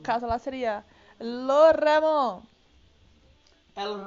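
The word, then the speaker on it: jamón: Carrom é, presunto é Ramon. Ramon. E se escreve Jamon.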